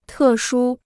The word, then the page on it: 特殊: tè shū